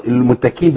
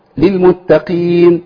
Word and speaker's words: للمتقين: دايما اقول للمشاهدين الكرام خد بالك اوعى تخاف انك تقع في ابدالها بكاف. لو اجيبها المتكين بالكاف هعمل ايه؟ هعمل تكلف. – صح. – اقول ايه اعدت للمتكين انا بعمل مجهود عشان اجيبها كاف لانها ده مش طبيعه نطق الحرف او الكلمه لفظ القران.